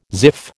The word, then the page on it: ziff: American English